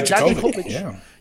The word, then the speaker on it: Dijakovic: Dijakovic. Did I just say that right? – Yeah, Dijakovic. Yeah.